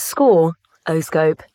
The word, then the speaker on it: Scoroscope: And joining us on video call, one of the people behind the new technology, Scoroscope. Am I saying that right? – Well, it's Score, O-Scope. like score, as in the final score, and horoscope.